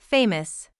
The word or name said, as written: famous